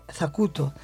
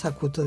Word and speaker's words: Zacuto: Zacuto. En algún momento se pronuncia Zacut y en otros Zacuto, depende,